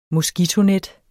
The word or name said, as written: moskitonet